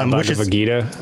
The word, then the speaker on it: Bhagavad Gita: So Gilgamesh of the Bhagavad Gita, I think is how you pronounce it. – Yeah, Bhagavad Gita.